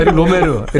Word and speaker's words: 에릭 로메르: 에릭 로메로 아, 그 에릭 로메르. – 아, 에릭 로메르. 네. – 에릭 네. – 로메로라고 제가 잘못 아, 네. – 발음했는데 아, 네.